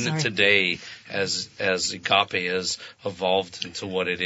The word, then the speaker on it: agape: Agape. Yes, it's Greek.